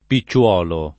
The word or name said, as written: picciuolo